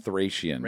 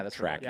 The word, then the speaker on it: Thracian: Is it it's spelled Thracian, but it's called Trachean. – Yeah, I thought it was Thracian. Wow. Yeah, it's Trachean.